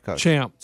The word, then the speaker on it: Champ: I know, was it Chump and then Morikawa? – No. Champ. – Sorry, I pronounced it wrong.